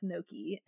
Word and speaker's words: gnocchi: And I was like, ganache, G-N-O-C-C-I. And she was like, that is not ganache. That's gnocchi. And Jessica looked at me, and she's like, that's gnocchi. And I'm like, but it says ganache. And she's like, no, it doesn't. That's gnocchi. The G is silent. And I'm like, wait, what? – Like, well, and C says S before I. Yeah. And CI says sh sometimes. Like, right? Huh? Like in vacation.